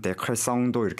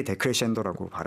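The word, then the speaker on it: decrescendo: 그래서 마치 철자만 보면은 데크레성도 이렇게 발음할 것 같은데 네. 정확하게 데크레션도라고 발음을 이탈리아계답게 합니다.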